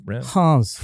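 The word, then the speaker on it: Reims: He spent this past season over in France playing for, how do you say, Rem? – Hans.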